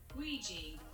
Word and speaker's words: Ouija: Ouija. – Ouija.